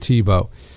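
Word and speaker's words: Thibaut: It's spelled T-H-I-A-U-B-T. It's an unusual spelling, pronounced Tebow.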